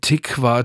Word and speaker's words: Tikva: Tikva Theater.